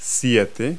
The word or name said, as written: seven